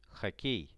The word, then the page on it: hockey: Russian